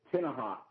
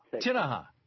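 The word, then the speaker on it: Tenaha: We've got two different boards and is it Tamaha, Texas? Do I have the pronunciation? – Yes, sir. – Hey, I'm right here. What's up? – It's Tinaha. – Tinaha. Okay.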